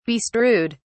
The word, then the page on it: bestrewed: British English